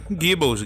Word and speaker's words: Gibbons: Ou tô falando errado aí? Vocês corrigem aí meu inglês. É, Gibbons, Gibbons.